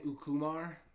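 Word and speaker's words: Ucumar: I was saying Ukumar. – That sounds right.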